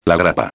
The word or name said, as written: la grapa